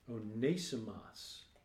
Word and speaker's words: Onesimus: Onesimus is the English rendering. Onesimus is actually how it's said in Greek.